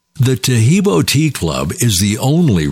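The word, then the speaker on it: Taheebo: Tahibo is spelled T-A-H-E-E-B, like boy, O, then the word T.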